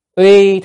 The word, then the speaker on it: uy: Uy thế vô kỷ, cái chữ này đọc là uy hay oai cũng được, oai thế vô kỷ hay uy thế vô kỷ, tức là cái oai phong với uy lực của mình á, đọc uy thì nó rõ hơn, nghĩa nó gần hơn.